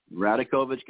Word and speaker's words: Radakovich: The Radovich guy, I'm sorry, I can't say his name. – Radakovich. – Radakovich guy, the guy from Baylor, Mark Rourke.